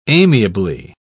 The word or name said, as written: amiably